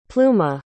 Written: pluma

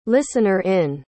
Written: listener-in

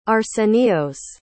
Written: arsenios

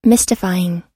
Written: mystifying

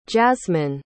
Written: jazzmine

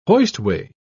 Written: hoistway

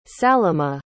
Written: salimah

